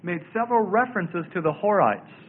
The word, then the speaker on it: Horites: But the significant thing about the Hurrians, spelled H-U-R-R-I-A-N-S, was that they are the long-lost Horites of the Bible. Horites. Sometimes you'll see it spelled like this, Horim. The Horim. But the Horites, spelled H-O-R-I-T-E-S, This is the home of the Horites.